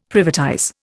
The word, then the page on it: privatize: Indian